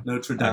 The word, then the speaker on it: Notre Dame: Also, is that how you say it? Do you say Notre Dame? – I think it's Notre Dame. – Notre Dame. – I think you're right.